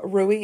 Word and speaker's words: Rui: Now, Rui is pronounced, as both Sophia and Mariana both told me, you kind of roll the R like it's French.